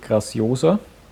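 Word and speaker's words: Graciosa: beziehungsweise wie es anscheinend ausgesprochen wird, Graziosa.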